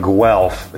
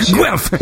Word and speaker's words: Guelph: But anyway, I went to a show in Guelph. Is that how you pronounce it? – Yes. – It sounds like you sneeze and someone's like, bless you. Yeah. – Guelph.